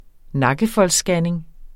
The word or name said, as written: nakkefoldsscanning